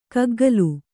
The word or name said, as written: ಗುಗ್ಗುಲು